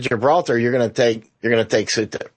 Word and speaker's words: Ceuta: Some people call it, I call it Ceuta, which is what a lot of people call it, and others call it Quetta, but Ceuta is what I call it, and that's what a lot of people call it, Ceuta, okay. Right? So it's spelled C-E-U-T-A, Ceuta, right?